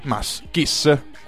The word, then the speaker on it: Mascis: Mascis. Questa è la pronuncia giusta. Si chiama Mascis. Se lo cercate in internet. – M-H-Ascis. – No, Ascis, come piacerebbe a te, ma M-A-S-C-I-S.